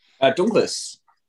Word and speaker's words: Dunkels: we're moving on to the Dunkels, as I pronounce it. How would you pronounce it? You've been there. – Dunkles. – See, I knew I'd get it wrong.